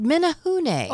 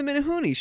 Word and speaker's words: Menehune: They're called Menehune. – Oh, the Menehune, sure. – Yeah, it's M-E-N-E-H-U-N-E, Menehune. Because in the Hawaiian language, you pronounce all the vowels, which I worked there in radio.